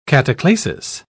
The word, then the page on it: cataclasis: British English